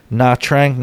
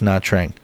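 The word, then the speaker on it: Nha Trang: N-H-A Trang. Na Trang. Naha. Na Trang.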